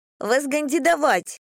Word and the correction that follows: вознегодовать: «Вознегодовать». Поправляю, по слогам говорю. «Повтори». Никита повторяет правильное произношение.